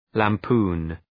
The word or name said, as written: lampoon